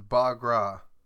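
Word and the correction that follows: Bhangra: The next word is Bangra. – Bong? – Bangra. – Bangra? – Yes. – B-O-N-G-R-A-H.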